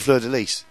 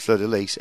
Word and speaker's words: Fleur-de-Lis: So, anyway, the CD is called Fleur-de-Lis, although it should be called Fleur-de-Lis, actually. – Right. – The correct pronunciation is Fleur-de-Lis, L-Y-S, but they don't stand in the way of either pronunciation or spelling.